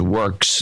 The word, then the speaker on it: WWORX: In Norwell, Massachusetts, pronounced works, W-W-O-R-X.